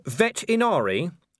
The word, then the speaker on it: Vetinari: "Lord Vetinari," said Vimes helpfully, putting a slight stress on the first syllable and hearing the growl on cue.